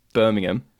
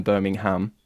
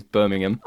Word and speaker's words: Birmingham: And again, you guys, I know you guys definitely pronounce this differently in America, but it's Birmingham rather than Birmingham. Oh, it's again, that's just the way we say things. Birmingham.